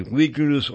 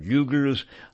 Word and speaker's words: Uyghurs: There has been a lot of publicity about the Uyghurs, or some pronounce that Uyghurs. You will see that variously spelled as U-I-G-H-U-R or U-Y-G-H-U-R.